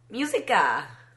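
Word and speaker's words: música: Musica. – Why did you call it that? – That's how you say it in Spanish.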